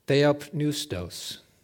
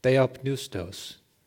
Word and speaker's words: theopneustos: The Greek word for inspired is theopneustos, theopneustos. The P is not silent. And it's related to the Greek word for spirit, pneuma, where the P is silent.